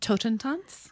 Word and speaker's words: Totentanz: It's called Totentanz.